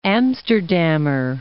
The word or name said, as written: Amsterdammer